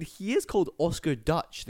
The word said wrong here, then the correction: Deutsch: the guy who made the Odeon. No, Deutsch. Oscar Deutsch. – So? – Which means German, right? – Deutschland is Germany. – It's spelled D-E-U-S-T-C-H.